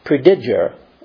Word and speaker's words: Prediger: In fact, when Martin Luther titled his book, he used the word prediger, as the best I could pronounce it, P-R-E-D-I-G-E-R, and it meant preacher.